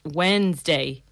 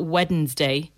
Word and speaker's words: Wednesday: The word is pronounced Wednesday, not Wednesday.